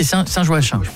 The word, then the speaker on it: Saint-Joachim: À mon avis, c'est Saint-Joachim. – Saint-Joachim. – Souvent, si ça s'écrit Joachim, il faut prononcer Joachim. – J-O-A-C-H-I-M.